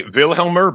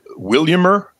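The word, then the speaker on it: Vilhjalmur: A lot of people say Wilhelmer, but he pronounced it Williamer.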